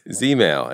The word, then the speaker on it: Zmail: Is it Zmail? Zmail? – Yeah, you got it. Right. ZZ Top? Zmail, as in catch some Z's.